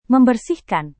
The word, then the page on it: membersihkan: Indonesian